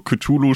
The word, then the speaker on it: Cthulhu: Und jetzt, nachdem du das eben auch schon äh, so ausgesprochen hast, also Cthulhu spricht man eben auch nicht mit TH, sondern.